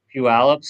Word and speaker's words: Puyallup: I think he only posted this question because he knows I can't pronounce it. So, what does think of the? – Puyallup screams. – Thank you.